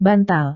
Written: bantal

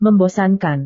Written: membosankan

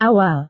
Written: awal

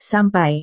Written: sampai

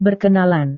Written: berkenalan

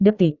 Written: detik

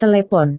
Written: telepon

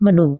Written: menu

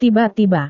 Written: tiba-tiba

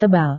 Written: tebal

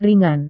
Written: ringan

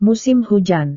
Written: musim hujan

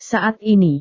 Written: saat ini

